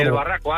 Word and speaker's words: Barraco: Desde el Barraco, Ávila.